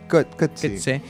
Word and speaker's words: Coetzee: Está, el domingo está como la Paul Auster y Coetzee, que nunca sé cómo se dice. – Coetzee.